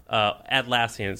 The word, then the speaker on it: Atlassian: Yeah, Atlassian, spelled with an A. – A-T. – A-T.